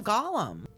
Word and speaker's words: Gollum: You call him Golem. – You don't call him Golem. You call him Gollum. – It's spelled Gollum, though, right? Isn't it G-O-L-L-E-M? – I think, but it's just spelled different.